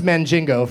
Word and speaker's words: Mandingo: It's pronounced Mandingo, first of all.